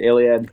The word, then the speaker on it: Iliad: And correct me if I'm wrong, Iliad, am I pronouncing that correct? – Yeah, the Iliad.